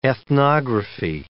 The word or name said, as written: ethnography